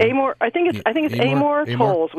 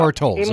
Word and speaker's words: Amor Towles: It's Amor Towles, is it? I don't know how you pronounce his name. – Amor Towles, we figured out. – Amor Towles, okay. – Amor Towles.